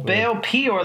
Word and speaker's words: Baal Peor: Well, like P-E-E, and then your, Y-O-U-R, P-E-O-R, one word. – Well, Baal Peor, the way I wrote it... – That's how you would say it. – That's the pronunciation. – But yeah, P-E-O-R is how you spell it.